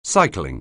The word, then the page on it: cycling: anglais